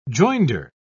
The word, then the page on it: joinder: British English